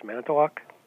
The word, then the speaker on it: Manitowoc: Yes, Manitowoc.